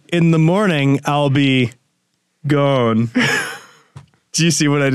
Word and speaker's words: Ghosn: Because Carlos Ghosn's name is pronounced gone, and it sounds a little like gone.